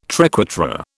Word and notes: triquetra: British English

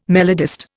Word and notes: melodist: Indian

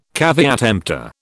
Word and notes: caveat emptor: British English

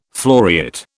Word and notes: floriate: British English

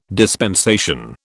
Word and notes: dispensation: British English

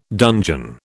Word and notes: dungeon: British English

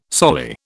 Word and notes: soli: British English

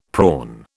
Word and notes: prawn: British English

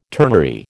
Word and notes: ternary: American English

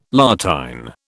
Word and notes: La Tène: British English

